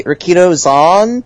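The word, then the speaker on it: Rikidōzan: It always feels like it should be Rikido Zan. – Okay. – But it's not. It's Riki Dozan. It's weird. It's a weird name. – Riki Dozan.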